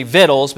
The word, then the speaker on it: victuals: That's actually the correct pronunciation of the word victuals, which just has to do with food.